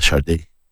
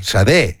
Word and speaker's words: Sade: Iba a decir, porque en inglés se podría pronunciar como Sad o Shade. – No, claro. Sade. Con acento en la E.